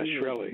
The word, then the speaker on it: Shkreli: The guy, Shkreli, is that how we say his name? – Yeah, Shkreli.